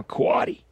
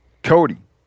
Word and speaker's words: coati: We were hiking up the road, and he's like, "Oh, you know, I think this poop here is from a coati." – Cody. – Well, so there's a couple of different ways to pronounce it.